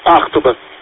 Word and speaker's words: Ахтуба: Гостиница Ахтуба, у нас ударение на первую буковку, Ахтуба.